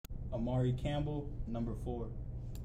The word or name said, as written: Amare Campbell